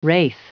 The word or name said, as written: wraith